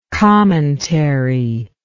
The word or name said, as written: commentarial